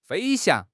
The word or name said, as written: 飞翔